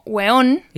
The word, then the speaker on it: huevón: Entonces el huevón me parece que en otros países, no, sabría decirte si México o qué otro, no quiero meter la pata, pero me parece que se pronuncia más como hueón.